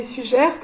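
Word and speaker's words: Suger: L'abbé Sugère pour le 12e siècle et Saint Louis pour le 13e.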